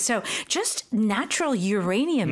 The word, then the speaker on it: uranium: 그리고 사실 우리는 우라늄이라고 발음을 하거든요. – Yes. – 근데 쌤은 지금 조금 다르게 하신 것 같아요. – We say uranium.